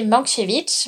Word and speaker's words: Mankiewicz: Mankiewicz. Marc, j'espère que je le dis bien. – C'est bien.